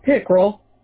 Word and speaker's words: Pickrell: Yeah, Pickrell. – Can you spell that? – Mm-hmm. It's P as in Paul, I-C-K-R-E-L-L.